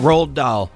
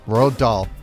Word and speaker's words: Roald Dahl: Can you name the movie that he stars in from 2005 that is based off the, how do you say it again? – Roald Dahl. – Roald Dahl.